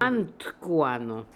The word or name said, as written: Antkooano